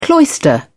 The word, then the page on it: cloister: British English